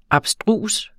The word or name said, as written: ABSTRUSE